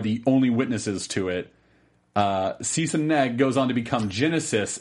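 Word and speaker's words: Sise-Neg: It's a big, long, ridiculous epic that ends with Sisson Neg. I can never say his name right. Sisson Neg. – Genesis backwards.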